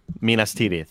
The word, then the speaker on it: Minas Tirith: Did I say it right? – Minas Tirith. – Minas Tirith. – Yeah. – Ath.